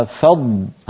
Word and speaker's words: فضل: قبل أن نختم ننبه على كلمة فضل الله التي مرت معنا في الآية 64، فلولا فضل هكذا تنطق لأن فيها ضاد ساكنة وبعدها لام مضمومة، فلولا فضل، لما نقول فضل هنا ينتهي صوت الضاد، فضل ولا نقول فض،